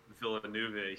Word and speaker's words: Villeneuve: I think it's Villeneuve. – Okay, Denis Villeneuve. Villeneuve.